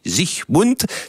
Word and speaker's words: Siegmund: Alors, l'homme fort qui a permis cette victoire, c'est Ulrich Siegmund, en allemand ça se prononce Siegmund, pas d'alga, je n'ai pas dit sich heil, j'ai dit Zichmund.